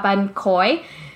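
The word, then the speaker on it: bánh khoái: Because people hear me and they're like, oh, you mean ban khot. And I'm like, no, not ban khot from Vung Tao. I'm talking about ban khoi, which is like a ban sail, but it's thicker and it's crunchier.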